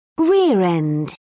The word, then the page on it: rear end: British English